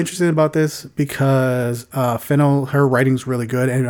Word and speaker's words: Fennell: Oh yeah, sorry, promising young woman uh, director Emerald uh, Fen- how you say Fennell.